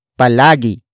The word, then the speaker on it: palagi: For example, nagluluto palagi si Jack ng itlog. Nagluluto palagi si Jack ng itlog. Nagluluto palagi si Jack ng itlog. It means Jack always cooks an egg. Word for word, it's nagluluto palagi si Jack ng itlog.